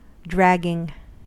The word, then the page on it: dragging: American English